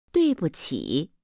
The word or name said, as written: duibuqi